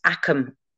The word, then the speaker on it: agam: Yeah, so it's like in Gaelic, the G's are a K, so it's like it's actually written AGAM, but you pronounce akam. It's like it's Akum.